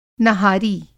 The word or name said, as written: नहारी